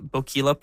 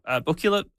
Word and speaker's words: Boquila: And I had never heard of the chameleon vine, also known as the Boquila plant. Kind of rhymes with tequila. That's how I think of it. But it is so cool. So Boquila, flowering plant found only in temperate forests in Chile and Argentina.